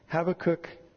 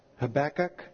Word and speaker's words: Habakkuk: Habakkuk is the way I'm pronouncing it. It could also be pronounced Habakkuk. I may inadvertently use both ways.